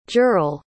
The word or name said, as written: jurel